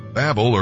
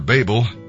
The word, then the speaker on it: Babel: Babel, or Babel, people do pronounce it different ways, means confusion.